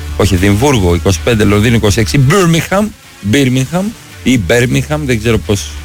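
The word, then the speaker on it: Birmingham: Οι πόλεις προφέρονται όπως λέει το στοίχημα. Πίτερμπρο, Πιτερμπόρο, Μίτλεσμπρο. Ό,τι γράφει εδώ πέρα το δελτίο. Μπέρμιγχαμ, τέλος.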